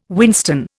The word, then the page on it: whinstone: Indian